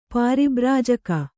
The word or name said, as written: ಪಾರಿಬ್ರಾಜಕ